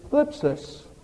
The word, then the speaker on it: thlipsis: And the word tribulations there in the Greek is thlipsis. Very difficult to pronounce. You have to get that THL sound up there in your upper mouth, the roof of your mouth.